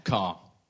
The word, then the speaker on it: Kerr: It's spelt like the Kerr, like but it's pronounced like the vehicle.